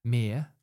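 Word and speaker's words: Myr: Mir.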